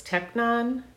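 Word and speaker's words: teknon: And one of them was technon, if I'm pronouncing it correctly, and hoios. Is that how you believe it was pronounced? – Yeah.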